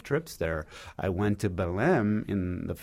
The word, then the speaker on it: Belém: I know your last article was Belém on Belém, Brazil. Is that right? Am I saying it right? – You're exactly saying it right.